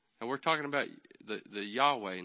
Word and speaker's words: Yahweh: In the JPS commentary, it says, Yod Hey Vav Hey was probably pronounced Yahweh in biblical times. The Vav was pronounced like the English W.